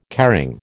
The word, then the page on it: caring: American English